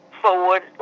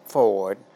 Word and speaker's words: Ford: My maiden name was Ford. – spell that I can't hear very well. Board? – Ford, like the car. – Okay. – Ford.